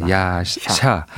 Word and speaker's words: 야샤: 야사. 예, 야샤. 야샤. 야사 네. 하이패츠라는 이제 바이올린 연주자가 있어서 그렇죠. 예, 이 발음이 좀 비슷한데요.